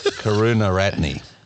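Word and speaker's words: Karunaratne: Chamika Karuna Ratnani. I had this pronounced the bloody Sri Lankan, you know who I'm talking about. – No, say it again. – Karuna Ratne. – Okay, that's better.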